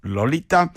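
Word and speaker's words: Loleatta: Lolita, que no se escribe como nosotros escribimos Lolita, sino en este caso, Holloway lo escribe L-O-L-E-A, dos T's A's, Loleata. Bueno, pronunciado Lolita, álbum 1980, titulado como esta canción, Sensación Amorosa, Love Sensation.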